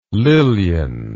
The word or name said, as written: lilyan